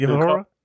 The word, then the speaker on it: Uhura: The next thing was that, I don't know if I'm gonna butcher her name, Uhura, Lieutenant Uhura.